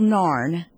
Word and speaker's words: NARN: But the easiest way to get to our website is just to Google NARN, which rhymes with barn. – N-A-R-N. – N-A-R-N, NARN.